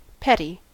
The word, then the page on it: petty: American English